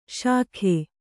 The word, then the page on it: ಶಖೆ: Kannada